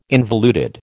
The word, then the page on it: involuted: American English